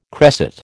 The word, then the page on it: cresset: American English